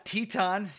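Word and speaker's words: Titán: We got Teton. So now we got CMLL. – Oh, is that how you say it? Okay. Not Titan. – Yeah. No.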